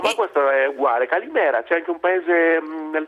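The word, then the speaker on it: Calimera (Καλημέρα): Calimera. – Ce lo ripeti bene? – Calimera, benissimo.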